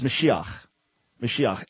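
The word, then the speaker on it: Mashiach (משיח): When you say Yeshua HaMashiach, that means you don't know Hebrew. It's Mashiach.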